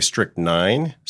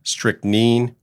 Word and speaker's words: strychnine: And in case you're wondering, before talking to Barb, I checked out the proper pronunciation for strychnine, and apparently one can say strychnine, strychnine, or strychnine. It's all allowed.